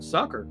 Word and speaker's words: succor: I mean, they actually pronounce this, I said succor, but they actually pronounce this sucker,